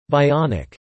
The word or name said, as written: bionic